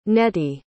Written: nettie

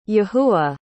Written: yahuah

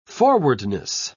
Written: forwardness

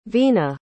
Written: vina

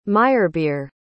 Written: meyerbeer

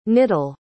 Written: knittle

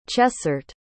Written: chessart

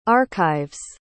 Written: archives

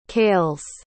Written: calles

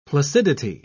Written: placidity

